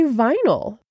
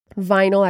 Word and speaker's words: vinyl: or a vinyl. Vinyl, I repeat, because every one of my students pronounced that incorrectly. Vinyl, V-I-N-Y-L, vinyl.